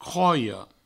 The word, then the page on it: croia: croio